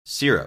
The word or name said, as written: syrup